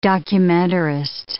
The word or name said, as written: documentarist